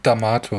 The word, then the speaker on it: D'Amato: Spricht man den so aus? D'Amato? D'Amato? D'Amato, ja.